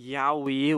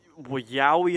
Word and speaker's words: Yowie: So if you're not familiar, the Yaoi, which is spelled Y-O-W-I-E, this is pretty much an Australian Bigfoot.